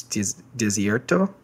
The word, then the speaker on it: Desierto: I'm going to pronounce it in English Spanish, but it's Desierto.